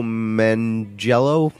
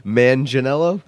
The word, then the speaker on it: Manganiello: I don't know how to say his name, but Joe Manganiello and his wife Sofia Vergara. – Who's Joe Manganiello? – We're pronouncing that so wrong. Manganiello? Joe Manganiello, I believe.